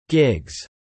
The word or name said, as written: gigs